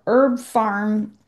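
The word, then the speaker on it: Herb Pharm: So here in the U.S., I am a big fan of Herb Farm, it's spelled P-H-A-R-M.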